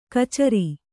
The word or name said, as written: ಖಚರ